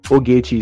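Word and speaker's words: Ogechee: I am Ogechi. I mean, the Ogechi is spelled O-G-E-C-H-E-E. I am Ogechi on Instagram.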